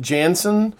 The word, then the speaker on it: Janzen: Around 8:45 p.m., it had been raining that day, and one of the neighbors, Mrs., I believe it's, I'm going to pronounce it Jansen, it's had J-A-N-Z-E-N, she took her dog out for a walk.